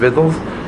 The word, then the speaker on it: victuals: Vittles is spelled victuals, V-I-C-T-U-A-L-S, but it means vittles, pronounced vittles.